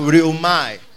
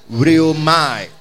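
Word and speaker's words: rhyomai: Deliverance comes from the Greek word rheume or rheumai. Rheumai sounds better because that's how the phonetic is. It is spelled R-H-Y-O-M-A-I. R-H-Y-O-M-A-I. Rheumai.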